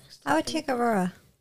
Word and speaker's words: Aurora: have to go through what Aurora, I don't know how to say her name. – Aurora story have to happen.